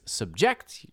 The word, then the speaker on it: subject: It's subject yourself to this with the stress on the second syllable. Exactly. Because otherwise it changes the meaning.